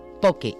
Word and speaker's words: poke: y se pronuncia como una palabra llana, es decir, se diría poke, y además no hay ni que escribirla en cursiva, o sea, se pone tal cual, P-O-K-E.